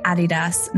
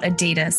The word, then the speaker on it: Adidas: And it's actually Adidas, not Adidas. So Adidas is how the Americans say it, but it's actually a German brand in its roots, and they pronounce it Adidas or Adidas.